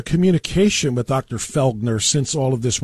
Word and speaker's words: Felgner: Your co-patent holder, or the co-inventor, if you will, of this tech is Dr. Flegner, right? Philip Felgner, excuse me. – His name is actually pronounced Felgner. – Felgner, yeah.